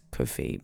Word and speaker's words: Ko-fi: Maybe it's Ko-Fi, but it's like Hi-Fi, Ko-Fi.